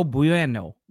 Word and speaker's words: bueno: I just randomly, like, I don't even know why I said It's like a W. No Bueno. – No Bueno. – Bueno.